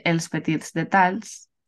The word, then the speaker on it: els petits detalls: Vale, yo no le iba a pronunciar tan bien, iba a decir els petits detalls. – Sí, pero, está bueno. bien, está bien pronunciado.